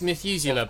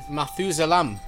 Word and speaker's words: Methuselah: It is Methuselah, but they said Methuselam.